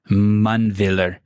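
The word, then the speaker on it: Mundwiler: Mundwiller. You pronounce the W as a V? – Yeah.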